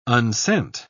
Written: unsent

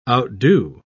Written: outdo

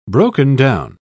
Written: broken-down

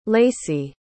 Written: lacey

